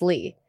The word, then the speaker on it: Lie: I always say I never lie, so it's Lee, but it's spelled lie.